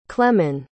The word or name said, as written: clemon